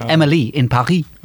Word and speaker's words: Emily in Paris: However, there was a bit of a shocker yesterday when Netflix tweeted, and they said, friendly reminder that Emily in Paris is supposed to be pronounced with a French accent so that Emily and Paris rhyme.